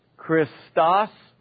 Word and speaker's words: Christos: Second word is pronounced Christos. You know, the sigma, tau go with the omicron, so it syllabifies right there.